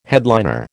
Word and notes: headliner: American English

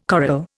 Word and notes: coral: Indian

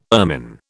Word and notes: ermine: British English

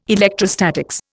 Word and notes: electrostatics: Indian

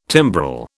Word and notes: timbrel: British English

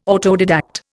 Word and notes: autodidact: Indian

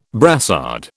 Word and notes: brassard: British English

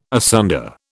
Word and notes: asunder: British English

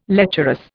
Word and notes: lecherous: Indian

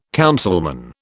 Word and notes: councilman: British English

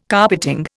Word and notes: carpeting: Indian

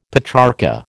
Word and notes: Petrarcha: American English

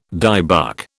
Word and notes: dybbuk: British English

